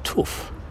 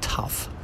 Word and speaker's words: tuff: And it's built of what's called Brisbane tuff, T-U-F-F. It's an unusual, not tough, tuff. It's pronounced.